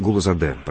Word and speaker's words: Гулузаде: Галузаде, если не ошибаюсь, да? Правильно сказал? – Да, ну, немножко вы выговорили именно с московским, я бы так сказал, акцентом. Вафа Мирзович Гулузаде, правильно? – Ну, ради бога, извините, пожалуйста, не хотел обидеть.